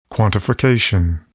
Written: quantification

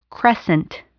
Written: crescent

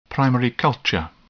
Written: primary culture

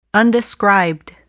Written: undescribed

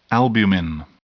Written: albumin

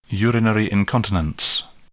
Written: urinary incontinence

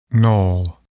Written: null

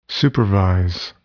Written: supervise

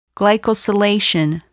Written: glycosylation

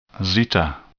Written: zeta

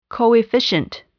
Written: coefficient

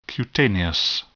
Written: cutaneous

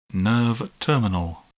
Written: nerve terminal

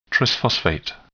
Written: trisphosphate

